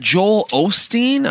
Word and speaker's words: Joel Osteen: But, but you know, that's interesting, like that Joel Osteen.